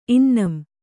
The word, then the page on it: ಇನ್ನಂ: innam